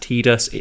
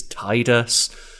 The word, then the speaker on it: Tidus: 10, Tidus, and I know some people say it's pronounced Tidus, it's Tidus.